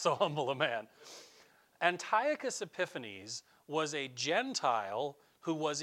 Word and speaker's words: Antiochus Epiphanes: It came from this guy, Antiochus Epiphanes. That's how you say his name.